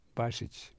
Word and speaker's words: Bašić: Stipe, S-T-I-P-E, Bašić, B-A. To pronounce it in English, you'd have to spell it B-A-S-H-I-C-H. But I left it basic because that's the way we pronounce it. Okay. See, if I gave it Bašić, the anglicized, it'd be a completely different name.